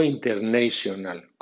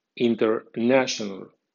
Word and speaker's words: international: Es final en nation, nation, y no es final en national, national, o bien international, no international, como vulgarmente suele usarse, sino international. Cuando